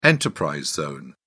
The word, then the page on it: enterprise zone: British English